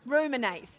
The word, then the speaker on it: ruminate: Remunerate. Not such a good word. Is that the right? Did I say it right? He's not listening. Ruminate. Yeah. Ruminate.